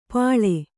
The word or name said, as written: ಪಳೆ